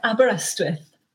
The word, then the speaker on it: Aberystwyth: And won the, and correct me if I'm wrong on this pronunciation, I've been to Wales twice, Aberystwyth University Prize for Short Fiction? – Yeah, Aberystwyth. – Okay. Okay, good.